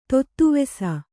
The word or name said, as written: ತೊತ್ತುವೆಸ